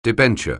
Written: debenture

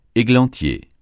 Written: églantier

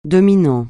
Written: dominant

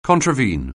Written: contravene

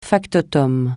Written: factotum

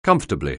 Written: comfortably

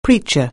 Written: preacher

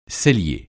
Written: cellier

